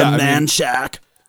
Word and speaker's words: Manchaca: Go down to Manchac. It's spelled Manchaca, but it is not pronounced like it.